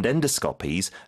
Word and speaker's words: endoscopy: 그 다음에, 이제 미국 발음은 endoscopy라고 하는데, 영 발음 어떻게 된다고요? – Endoscopies. – 주로 앞에 힘을 주네요.